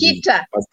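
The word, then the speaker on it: Nikita Mazepin: And then moving to Haas, we have the return of a Schumacher, and then we have Khatema Mazepin. I don't know how to pronounce Nikita. – Nikita, yes.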